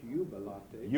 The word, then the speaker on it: Jubilate: I think it's Jubilate. – Jubilate, okay.